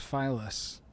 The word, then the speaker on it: Filous: I looked up the pronunciation of that one. F-I-L-O-U-S, Phyllis.